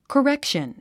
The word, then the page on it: correction: kərékʃən; コレェクション